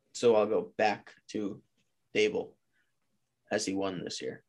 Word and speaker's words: Daboll: I'm taking Brian DeBall. Hope I said that right. DeBall. Yeah. What? – Brian Daboll. – Daboll. Dude, it's spelled D-A-B-O-L-L. There's no Daybell. It's not, where the hell did that come from?